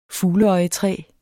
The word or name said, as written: fugleøjetræ